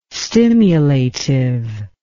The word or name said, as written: stimulative